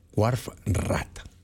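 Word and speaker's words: Wharf Rat: Así es que escuchemos a Grateful Dead con Trucking, That's It for the Water One, y también escucharemos Warf Raff, ¿cómo se pronuncia? Ah, no le atino, déjeme ver. Warf Rat, ellos son Grateful Dead.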